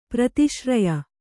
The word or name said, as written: ಪ್ರತಿಸೂರ್ಯ